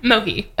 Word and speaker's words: Mohi: Mohi. – Okay. – As in no money, no problems with the third person singular masculine pronoun.